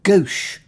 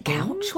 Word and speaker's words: gauche: Is it gouch? What's gouch? Gauche.